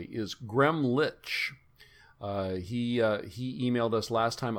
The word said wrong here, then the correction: Gremlich: I'm sorry I didn't give you a pronunciation guide for Gremlich. It's Gremlich.